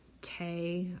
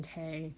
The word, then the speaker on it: Ttea: Tay, that's how I'm saying the name. It's spelled like T-T-E-A, but I'm saying Tay, is a witch, but she's like a bone witch, which is a dark witch.